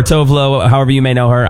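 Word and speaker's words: Tove Lo: Then you got Tovlo. Technically, she goes by Tuvalu, but no one pronounces it right.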